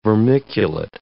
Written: vermiculate

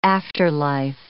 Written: afterlife

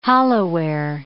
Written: holloware

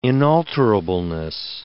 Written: inalterableness

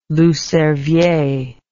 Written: loup cervier

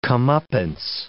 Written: comeuppance

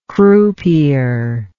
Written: croupier

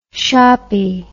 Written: shoppy

